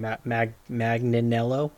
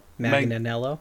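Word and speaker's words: Manganiello: I can't remember how to say his last name. Magnanello? – Manganello.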